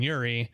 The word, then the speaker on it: Urey: And one of the first things you talk about is Miller and Urey. I think that's how you say his name.